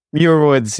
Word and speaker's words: Muir Woods: Yeah, Muir Woods.